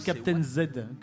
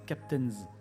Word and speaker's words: Captains: Et non pas les Captains Z, comme certains ont tendance à prononcer. La bonne prononciation, sachez-le, c'est Captains.